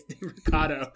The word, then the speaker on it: De Mercado: So one of the producers was like, "Hey, it's De Mercado." But on air, he did that weird thing where like he put the emphasis on the wrong syllable and all that.